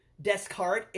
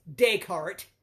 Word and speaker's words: Descartes: It's like people that say Rene Descartes. It's Descartes.